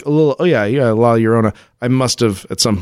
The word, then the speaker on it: La Llorona: The La Llorona is pronounced, yeah, La Llorona. Two L's together in Spanish make a Y sound, like El Pollo Loco.